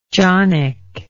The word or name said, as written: jonnick